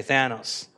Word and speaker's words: Thanos: Okay, Thanos.